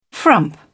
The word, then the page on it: frump: British English